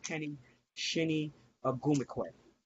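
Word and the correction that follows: Chiney: Chinay Ogumike, yep.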